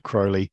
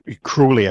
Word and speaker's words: Crowley: books, mainly Aleister Crowley, mm-hmm. Crowley, I should say. I should learn by now that's how it's actually pronounced.